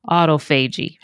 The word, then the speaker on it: autophagy: And what I love about fasting is the process of autophagy, or some people pronounce it autophagy. Autophagy, or autophagy, however you want to pronounce it, literally means the body feeds upon itself.